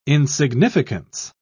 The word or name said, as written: insignificance